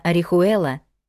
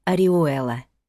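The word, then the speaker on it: Orihuela: Но в испанском языке не читается буква «Аче». Соответственно, «Орихуэла» на самом деле оказывается красивым словом «Ориуэла».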